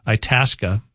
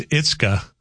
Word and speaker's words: Itasca: Texts are saying a new Valley News live anchor, pronounced Itasca, as Itzka on the news last week. Obviously not from the area.